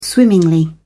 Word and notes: swimmingly: British English

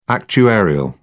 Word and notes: actuarial: British English

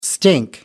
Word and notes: stink: American English

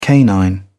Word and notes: canine: British English

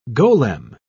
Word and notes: golem: British English

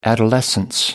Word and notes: adolescence: American English

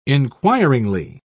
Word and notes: inquiringly: British English

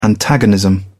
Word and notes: antagonism: British English

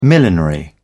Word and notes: millinery: British English

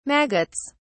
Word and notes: maggots: British English

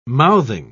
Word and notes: mouthing: British English

